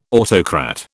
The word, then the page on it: autocrat: British English